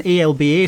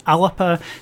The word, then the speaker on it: Alba: "Alapa," but it's written ALBA, so some people assume it's pronounced "Alba," but it's actually "Alapa."